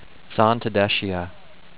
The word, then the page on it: Zantedeschia: zan-ted-DESH-ee-uh